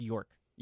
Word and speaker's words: yolk: It's pronounced York, even though there's an L in there.